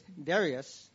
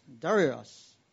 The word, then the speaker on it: Darius: In the eighth month, in the second year of Darius, I don't know how to say his name. I've heard it so different ways. It's Darius. Darius. The word of the Lord came to the prophet Zechariah, the son of Barachai, son of Ido. – Darius の第 2 年の第 8 の月に、イドの子、ベレクヤの子、預言者ゼカリアに次のような主の言葉があった。